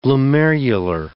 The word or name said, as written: glomerular